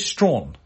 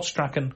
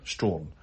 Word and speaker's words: Strachan: It is Strawn, okay? They pronounce Strawn. Not Strachan, Strawn. It's one of those words that there's no reason for you to believe that's how it's pronounced. Like a lot of British words. But it's pronounced Strawn. And that's that, really.